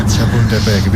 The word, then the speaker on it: Chapultepec: A ver si lo pronuncio del todo bien. Chapultepec. Mejor que algún cementerio francés. – ¿Dices? Chapultepec, bien.